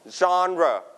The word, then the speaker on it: genre: It's called genre. Repeat after me. Genre. Listen to the audio one more time. – Genre. Genre. – Your turn. Genre. Genre. โอเคนักเรียนปลายทางออกเสียงตามด้วยนะครับคำนี้เน้นที่พยางค์แรกนะครับเป็นเสียงเจอนะครับเจอ genre. Don't pronounce it. Genre. No. No genre. นะครับ not genre.